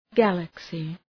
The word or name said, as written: galaxy